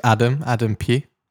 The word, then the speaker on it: Pugh: My name's Adam Pugh. – Adam Pugh, pronounced Pugh, right? – Yeah, Pugh, cool. P-U-G-H.